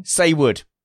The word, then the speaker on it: Saywood: Wait, how do you say that again? – Saywood. I said it correctly both times. – So it does rhyme with wayward then.